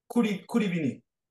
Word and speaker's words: Kouri-Vini: But the language that you've been studying, the language, and again, what's it pronounced again? Kouri-Vini? – Kouri-Vini.